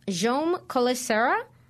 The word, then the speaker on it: Jaume Collet-Serra: It looks like Jaume Colicera. It is spelled J-A-U-M-E. C-O-L-L-E-T-S-E-R-R-A.